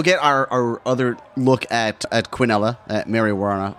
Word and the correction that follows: Warnoff: Warnoff. Warnoff. – I'm not going to say her name unless you're around. – Run it together. – Warnoff. – Warnoff. – There you go. That's it.